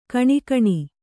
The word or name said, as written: ಘಿಣಿಘಿಣಿ